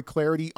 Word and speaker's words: Clardy: That's a lot at Troy Clardy on Twitter. The last name is spelled C L A R D Y.